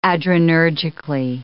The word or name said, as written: adrenergically